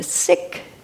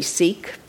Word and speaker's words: Sikh: Uh, the word means kitchen, but what it represents is the Sikh community. I used to say Sikh, probably many of you do, but they pronounce it Sikh.